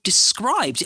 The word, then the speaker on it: Dscryb: We're also brought to you in partnership with, I don't know if you've heard of them, Alice, little company called Described.